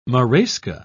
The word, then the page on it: marasca: British English